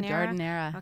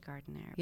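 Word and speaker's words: giardiniera: I have a I'm going to probably pronounce it wrong. Jardinera? – Yeah, Jardinera. – Okay, I always say Gardinera.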